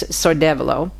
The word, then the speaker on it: Sordevolo: I always pronounce it wrong. It's Sordevolo. And this is way up north in Piedmont.